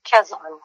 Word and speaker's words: Quezon: You were born in Quezon City, Philippines. Am I saying that right? – Quezon.